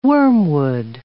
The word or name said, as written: wormwood